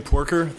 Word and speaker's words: Poorker: My name is Craig Porker. I'll spell that for you, P-O-O-R-K-E-R.